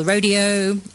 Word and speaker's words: rodeo: the rodeo, or rodeo, how do you say it? – Rodeo. – Rodeo, you yes. Rodeo, like me, rodeo.